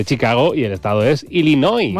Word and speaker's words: Illinois: Se pronuncia Illinois. – Sí, perdón, sí. – Illinois. – Illinois. Pues yo digo Illinois, si no entendemos todos. Porque como diga Illinois, nadie lo entiende.